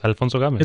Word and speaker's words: Alfonso Gomez: Because when I introduce myself, I like to say, hi, my name is Alfonso Gomez, but it is Alfonso Gomez.